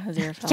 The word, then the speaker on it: Aziraphale: No, they're other demons, the occasional angel when he has to, and mostly Aziraphale. Is that right? Did I say it right? – Yeah, Aziraphale.